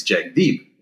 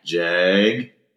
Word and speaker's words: Jagdeep: It's Jagdeep. It's spelled really simply. – Yeah. – Jagdeep. – Yeah. – And it's pronounced a little differently. But anyways, every year they would have trouble with Jagdeep.